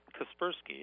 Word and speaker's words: Kaspersky: Kaspersky, yeah.